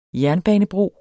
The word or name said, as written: jernbanebro